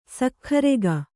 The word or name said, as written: ಸಕ್ಖರೆಗ